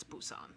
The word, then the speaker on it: Busan: And Train to Busan. I don't know if I'm saying it right. I assume it's Busan. – Yeah.